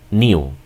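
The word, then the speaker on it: knew: Por ejemplo, el inglés británico, esta palabra que es el pasado de saber, la pronuncia como new, ¿ok? Y el inglés americano solamente dice no, ¿ok?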